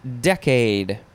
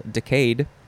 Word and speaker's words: decade: You could say it with the emphasis on the second syllable, decayed. And then in 1B, in the 1B definition, you can say it, how is the, oh, decad. Instead of decade, you can say decad.